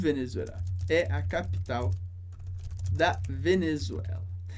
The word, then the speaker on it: Venezuela: Interessante sobre a pronúncia do nome do país. É mais comum que a gente veja em francês sem o acento. Mas, na verdade, o que a gente diz em francês é Venezuela.